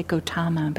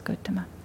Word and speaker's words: Gotama: I'm using that pronunciation, that's closer to the Pali. Some people say Gotama, but the Pali is Gotama.